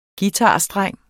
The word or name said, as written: guitarstreng